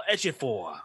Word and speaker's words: Ejiofor: Chiwetel Ejiofor.